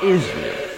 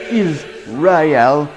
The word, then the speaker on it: Israel: It's not Israel. It's Israel. You might as well get it right. Get it straight. It's not Israel.